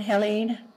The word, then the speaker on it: Haleen: How do you say that again? – H-A-L-E-E-N, Helene. – Okay. – Ingalls, I-N-G-A-L-L-S.